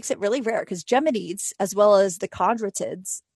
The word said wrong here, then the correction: Quadrantids: so quadrantids, sorry, I'm trying to pronounce it. It's spelled Quadrantid, happens later in the year.